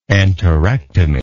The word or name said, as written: enterectomy